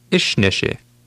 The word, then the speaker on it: Išneši: Išneši. – Did you remember to stress the first part of that word?